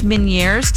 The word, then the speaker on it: Meniere's: I think it's Meniere's disease. – I think you're pronouncing it right. – Right, because I was talking to MC and I was describing this and he thought I said mini ears disease.